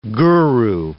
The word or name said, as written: guru